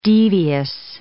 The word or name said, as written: devious